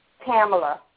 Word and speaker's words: Tamla: Pamela. – How do you spell that, with a T? – Uh-huh, T-A-M-L-A.